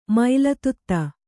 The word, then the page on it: ಮೊಲೆದುದಿ: moledudi